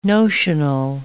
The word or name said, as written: notional